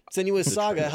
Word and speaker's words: Senua's Saga: Hellblade 2 Senua's Sage? – Saga. Right. – Saga. Whoops, yeah. Bad at taking notes. Senua's Saga, Hellblade 2.